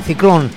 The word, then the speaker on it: Cyclone: Esto se escribe con Y al principio y acabado en E, pero se pronuncia igual, Ciclón.